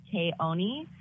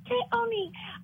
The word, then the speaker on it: Keone: It's actually pronounced Keone. And it's kind of funny because growing up, I would let people call me Keone, Kiana. And my mom would get so upset. She's like, no, it's Keone.